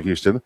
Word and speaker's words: Houston: Again, the New York tie, there's a street in New York, and it's spelled the same as Houston, the city of Houston, but it's pronounced a little differently. Houston, do you know why that is? – I have no idea. – It's obviously Houston's after Sam Houston. In New York, it's named after an attorney who was Scottish by birth, and he pronounced it Houston.